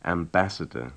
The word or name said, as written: ambassador